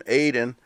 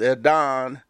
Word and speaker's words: Adon: It's not pronounced like we say, you know, in American English. It's kind of got a British flow to his name. I've been calling him Aiden, and it's not. It's Adon, and his Durden is Duda or something.